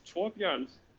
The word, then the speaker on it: Torbjørn: My full name is Torbjørn Sidolf Pedersen, which is a very Nordic name, and people they just can't work their way through it.